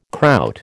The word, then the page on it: Kraut: American English